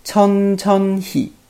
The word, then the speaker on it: 천천히: 嗯，好，我们来看一下这个句子里面的单词， 천 천 히 啊， 천 천 히。啊，它正确的发音应该是这样的，但是在读的过程当中啊，因为读的比较快嘛，所以听上去有点像 천 천 히 是吧？ 천 천 히 啊，